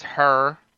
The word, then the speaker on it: Heir: H E I R, like hair.